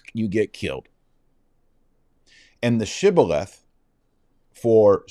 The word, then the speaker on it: shibboleth: And if they couldn't pronounce it right, they said sibboleth instead of shibboleth, they knew what was up, and they killed him.